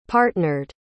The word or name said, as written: partnered